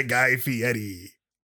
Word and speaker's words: Guy Fieri: Do you like eating food cooked by Guy Fieri? – Wow.